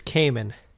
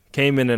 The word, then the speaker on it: Kamin: I think it's Kamin and Aline. – Kamin and Aline.